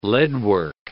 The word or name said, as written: leadwork